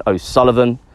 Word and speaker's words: O'Sullivan: You can catch me on Facebook at Taurean O'Sullivan. My name is spelled T-A-U-R-E-A-N. O'Sullivan is O-S-U-L-L-I-V-A-N.